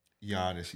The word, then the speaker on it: Giannis: I thought you pronounced it Giannis. – Giannis, yeah, it is Giannis.